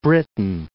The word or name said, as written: Britain